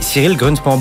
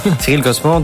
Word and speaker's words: Cyril Grunspan: Et Cyril Grunspan. Bonjour Cyril, est-ce que je prononce bien votre nom Tout à fait. Cyril Grunspan, directeur de l'Institut des crypto-actifs, justement, au pôle Léonard de Vinci.